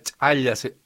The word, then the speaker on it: chaya: Y la chaya, bueno, también se produce, la chaya, es así como se pronuncia, sí, en quechua.